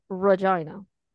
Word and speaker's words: Regina: There's a city in Canada called Regina. And everybody thought he said vagina. Like, Twitter blew up with that. I'm like, that's how they pronounce the city name in Canada. Although it's spelled like Regina.